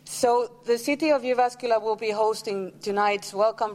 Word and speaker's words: Jyväskylä: So the correct way of pronouncing this place is Jyväskylä. But since all people coming here from abroad, they always say Jyväskylä. So we're kind of given into that joke, but it's Jyväskylä.